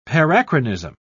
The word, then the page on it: parachronism: British English